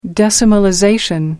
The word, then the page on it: decimalization: American English